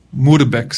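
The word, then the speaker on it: Moerbeke: Y ahí, por un fraile dominico llamado Guillermo de Muirbeck, Muirbeck sería la pronunciación correcta, de la región donde es la Holanda, por ahí, él tradució al latín.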